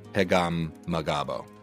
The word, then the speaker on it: Pegahmagabow: and I'm going to butcher this last name, Pagambagabo. – What? – P E G A H M A G A B O W. Pegam Magabo.